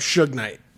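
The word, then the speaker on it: Suge Knight: Look up Shook Knight on Google for me, please.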